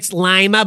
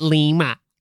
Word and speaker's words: Lima: It's Lima, but we pronounce it Lima. – No, it's the other way around. It's Lima, but we pronounce it Lima. Just like there's Berlin up by the lake, but they call that Berlin.